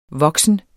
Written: voksen

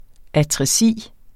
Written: atresi

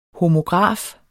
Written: homograf

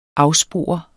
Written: afspore

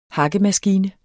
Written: hakkemaskine

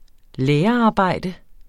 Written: lærerarbejde

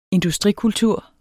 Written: industrikultur

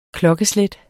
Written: klokkeslæt

